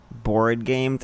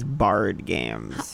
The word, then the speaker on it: board: You were pronouncing board games as bard games.